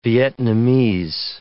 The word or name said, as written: Vietnamese